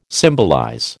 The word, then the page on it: symbolize: American English